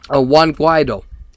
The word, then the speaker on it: Juan Guaido: But the socialist government and uh, Nicolas Maduro are under siege from the true president, from the true president um, Juan Guaido. And yes, that's how you pronounce his name.